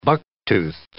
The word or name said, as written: bucktoothed